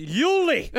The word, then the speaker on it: Jule: It's pronounced Yuli. Yuli Eisenbud.